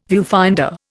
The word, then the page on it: viewfinder: Indian